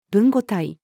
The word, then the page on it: 文語体: female